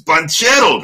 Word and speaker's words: Banchero: I got to tell you, man, the last time I was talking to William about this a few podcasts ago, dude, I had no idea that's how you pronounce it. I was always wondering when I heard it in college, by the guys who call the games in college when they were calling the Duke games, they're like Paolo Banchero. I'm like, oh my God, these guys, man, they're just butchering them. It's Banchero, man, right?